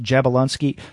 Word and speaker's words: Jablonski: Or I mean, it's pronounced Jablonski because they've spelled it two ways in the solicit. It's Jablonski.